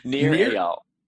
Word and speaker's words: Nir Eyal: Nir Eyal.